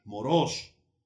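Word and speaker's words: moros (μωρός): Este, la palabra insensato es la palabra moro o moros, moros, que tiene acento en la segunda O.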